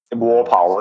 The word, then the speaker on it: Walpole: Did I pronounce his name right? – Yes. Walpole. It's like Walpole. – Smallpole. Believe me, it rhymes with smallpole.